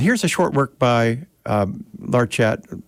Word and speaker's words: Larchet: His surname is a French name that he anglicized, being Irish, I suppose, or whatever, and pronounced Larchet, or Larchet. He put the accent on the first syllable.